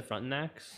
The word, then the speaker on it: Frontenacs: Is that how you pronounce it, the Frontenacs? – Frontenacs, yeah.